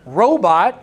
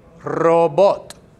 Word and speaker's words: robot: Because the word robot is an English word, and it is spelled exactly the same way in Spanish, except and instead of saying robot, you say robot. Robot. So here you go, a rolling of the R. R. O. And then the other O, right? So robot.